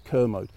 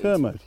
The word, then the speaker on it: Kermode: but it is Kermode, and the easiest way of remembering it is like Kermit. And you know, believe me, nobody gets it right, but that's it. And the actual proper pronunciation is Kermit.